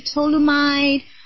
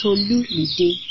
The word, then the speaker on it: Tolumide: I always have to spell it out because I've heard people say, "Is it Tolumide?" Or is it you know, there are different ways to pronounce it, but you know, it's Tolumide. Four syllable, TolumiDE, and it's spelled as TolumiDE.